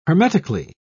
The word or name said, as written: hermetically